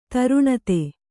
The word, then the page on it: ದಾರುಣತೆ: dāruṇate